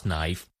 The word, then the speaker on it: knife: Knife The book is called How to Pronounce Knife because when I was a little kid I didn't know how to pronounce that word and then I showed it to my dad and he said you pronounce it as knife